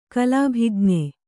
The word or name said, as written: ಕಲಾಭಿಜ್ಞ